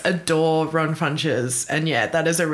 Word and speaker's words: Funches: Funches. – Funches.